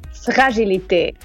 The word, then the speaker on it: Fragilité: Boggie, how do you say this album's name? – Hello, everyone. The album name is Fragilité.